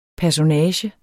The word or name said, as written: personage